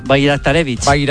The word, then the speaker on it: Bajraktarevic: El primero es un bosnio del PSV que se llama, voy a decirlo tal y como se escribe, Esmir Bajraktarevic. – Sí, señor, Bajraktarevic.